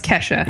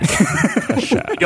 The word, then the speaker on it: Kesha: Again, it's pronounced Kesha. – Kesha. Don't pronounce a dollar sign.